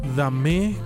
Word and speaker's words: Damé: Dame con acento en la E.